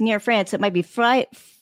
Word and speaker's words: Freiburg: The Freiburg Shrieker. Am I right in that pronunciation? I wonder. – I do not speak German, but that sounds right. – Freiburg.